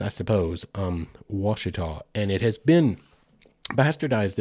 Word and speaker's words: Ouachita: It is not phonetic. It is an indigenous word, and it is spelled O-U-A-C-H-I-T-A. Any of you guys watching on YouTube, just watch me close my eyes and spell it out in my head. I didn't put it right in front of me. O-U-A-C-H-I-T-A. It's spelled kind of like Ouachita. Yeah, and it actually probably was sort of pronounced that way originally.